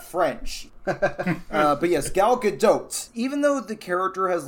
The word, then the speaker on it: Gadot: Gal Gadot, which, by the way, we were all saying it wrong in the Wonder Woman episode, is Gadot. I looked it up. I was pretty sure I knew that the T was pronounced.